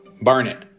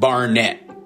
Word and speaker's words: Barnet: Like it rhymes with darn it. Barnet, not Barnett.